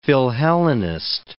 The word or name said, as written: philhellenist